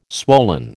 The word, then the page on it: swollen: American English